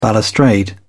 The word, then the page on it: balustrade: British English